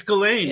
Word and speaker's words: Ghislaine: It's Ghislaine, yeah. – What the fuck? – I know, you look at how it's spelled, it's French, so apparently that's how the French spell Ghislaine.